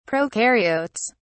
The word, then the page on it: prokaryotes: British English